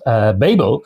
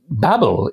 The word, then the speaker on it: Babel: I mean, one of my books, Babel, many people call it Babel, even though there's only one B in the middle.